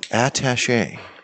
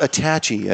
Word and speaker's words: attaché: With the at being attaché. – Attaché. – It's not attachy, as some people say, yeah, Barron's.